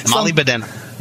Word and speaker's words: molybdenum: Molybdenum. – Huh? Yeah. – Molybdenum. – There you go. Molybdenum ore.